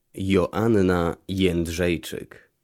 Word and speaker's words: Joanna Jędrzejczyk: Joanna Jędrzejczyk.